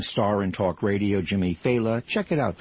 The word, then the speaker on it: Failla: His name is pronounced Failla. Failla. It's spelled F-A-I-L-L-A. So people go Failla. Failla. You know, but it's Failla.